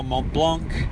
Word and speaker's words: Mont Blanc: She finished fifth at uh, Ultra Trail Mont Blanc. Is that how you say it? Yeah.